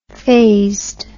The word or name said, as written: phased